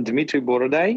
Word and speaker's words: Dmitry Boroday: Did I pronounce it right? – Yeah, Philip, you pronounce it like similar, like right, but a little bit not, but it doesn't matter. So I'm Dmitry Boroday, and I work in coffee like for last 17 years, I think so.